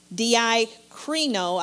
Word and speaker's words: diakrino: Now, that word doubt comes from the Greek word diakrino. I believe that's pronounced correctly, D-I-A-K-R-I-N-O, which means to waver, to be divided in judgment, to separate between two opinions.